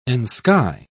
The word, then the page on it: ensky: British English